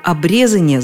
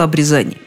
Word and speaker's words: обрезание: Но даже если бы я принесла еще десяток словарей, это никак не изменило бы ситуацию, поскольку обрезание звучит очень непривычно. Другое дело обрезание.